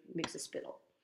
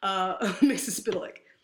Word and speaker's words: Mxyzptlk: Mxyzptlk. I totally said that wrong. Mxyzptlk.